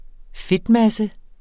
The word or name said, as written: fedtmasse